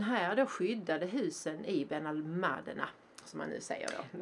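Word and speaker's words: Benalmádena: Men eh, betoning då på det andra a i eh, ordet. Och namnet Ben al med betoning på det andra året sägs komma från arabiskan Ben al-Madena, alltså i tre stavelser och betyder gruvornas söner.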